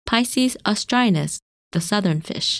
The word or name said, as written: Piscis Austrinus